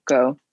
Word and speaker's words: Junco: But if you learn Spanish, like the U is pronounced like ooh, like Junco. – Junco. And then the J is not a J. It's also a U.